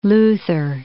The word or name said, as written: Luther